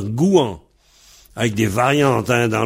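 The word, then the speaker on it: gouañv: Et alors, le contraire de han, c'est gouan. Gouan, G-O-U-A-N tilde V. Pareil, le V, on le prononce W ou on le prononce pas. Gouan ou gouan-ou. Gouan, gouan-ou. G-O-U A, N, tilde, V.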